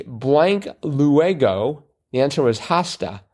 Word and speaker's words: hasta: So I was about to make a correlation between sera, which is Italian, and hasta, which is... – Hasta. – Hasta. – H is silent.